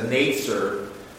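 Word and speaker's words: Netzer (נצר): In the Hebrew, the word for branch in Isaiah 11:1 is netzer. That's how you pronounce it.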